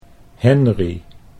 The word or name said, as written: Henri